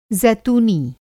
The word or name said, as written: ज़ैतूनी